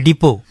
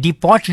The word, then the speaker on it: depot: डिपो, तो डिपो में जाहिर है कि DEPOT डिपोट लिखा जा रहा है लेकिन पढ़ने में आ रहा है डिपो।